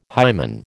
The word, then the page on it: hymen: American English